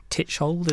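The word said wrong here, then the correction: Tschichold: is that how you pronounce it? – Chickold. – Chickold.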